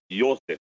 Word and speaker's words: Josi: Jag har jobbat i Schweiz. Jag säger Roman Josi.